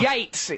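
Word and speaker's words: Yeats: Yates, it's pronounced.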